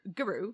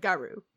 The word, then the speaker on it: guru: And so then I started saying guru instead of garu, which my mother would say garu.